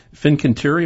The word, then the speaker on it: Fincantieri: How could an Italian firm like this, Fincantieri, am I pronouncing that right? – That's right.